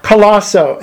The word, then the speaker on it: Colaco: My name is Robert Colaco, and that's spelled C-O-L-A-C-O.